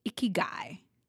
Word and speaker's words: ikigai: And it's the Japanese concept of ikigai. Right? I have such, like, it's spelled I-K-I-G-A-I. It took me forever.